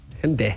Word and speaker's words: Hyundai: Es como Hyundai. – No, Hyundai. – Hyundai. – ¿Sí? Sí, a mí me dijeron en Corea.